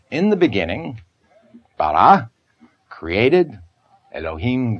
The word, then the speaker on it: Elohim: Bereshit bara Elohim. – Bereshit. – Bereshit. – Bara. – Bara. – Elohim.